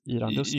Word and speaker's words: Irandust: Det, det, som är kul för Häcken nu är ju att jag kan aldrig uttala hans namn korrekt, men det kan nog du. I, Irandast. – Irandust. Irandust. Irandust. Irandust. Irandust ja.